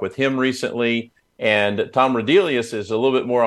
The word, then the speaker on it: Rudelius: And so could you introduce us to Tom Rudelius, I'm going to guess, is maybe how you say his name. – Yeah, well, congratulations on saying his name correctly, because it's not obvious.